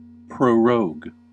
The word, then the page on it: prorogue: American English